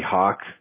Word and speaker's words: Hawk: My name's Elena, A-L-A-I-N-A. – A-L-A-I-N-A. Okay. My name's Sahak, but a lot of people just call me Hawk, H-A-W-K.